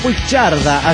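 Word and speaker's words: Puigcerdà: Un saludo desde Puigcerda, así se pronuncia, España.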